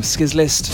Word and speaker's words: Sclist: And before that, I always get his name wrong going from Bristol, from Skizlist, S C L I S T.